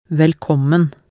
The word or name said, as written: velkommen